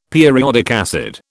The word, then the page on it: periodic acid: British English